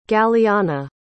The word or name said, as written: galiana